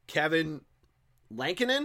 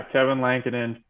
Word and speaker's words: Lankinen: They found this goalie, Kevin Lankinen. Is that how you pronounce his last name? – Yeah. – Yeah, this guy, 5-1-3. – Yeah, Kevin Lankinen, 5-1-3.